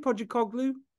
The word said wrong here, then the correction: Postecoglou: Postecoglou, right? – Postecoglou.